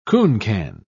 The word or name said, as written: cooncan